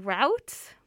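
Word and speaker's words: Routes: Oh, sorry, I pronounced that really badly. Sankofa African Route, as in the road, Canadian Roots.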